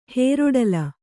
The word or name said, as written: ಹೇರೊಡಲ